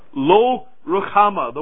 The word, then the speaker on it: Lo Ruhamah: That's how it's pronounced. It's that guttural H in the middle. Lo Ruhamah, the word Lo there, standing for the English word not.